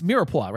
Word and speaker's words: mirepoix: Please pronounce this as mirepoix, right? The mirepoix, yeah, a staple in mirepoix, yeah, which is carrots, onions, and celery, respectively.